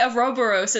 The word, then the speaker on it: Ouroboros: Ouroboros is what Google Dictionary says. – Ouroboros. – It's derived from Greek, so I know nothing about Greek. Can't help. – But it sounds, when you say Ouroboros, it sounds like how they try to say the Japanese names in Persona 5.